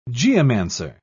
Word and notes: geomancer: British English

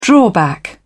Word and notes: drawback: British English

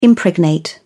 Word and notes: impregnate: British English

